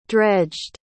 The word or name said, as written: dredged